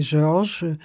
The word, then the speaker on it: George: Vous pouvez le prononcer à la française parce que c'est George, c'est le nom de mon mari décédé et il était un français bon teint.